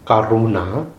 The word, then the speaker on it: Karuna: So, Karuna, K A R U N, long A, ah, Karuna, compassion.